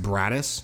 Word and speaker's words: Broaddus: name is spelled B-R-O-A-D-D-U-S, but it's pronounced Braddis. In his letter, he spells it B-R-A-D-D-U-S.